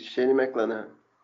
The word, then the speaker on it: Shane McClanahan: Também, bora continuar com a AL West e amigos, com o Shane McClanahan. É assim que se pronuncia? – Isso, Shane McClanahan.